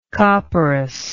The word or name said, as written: copperas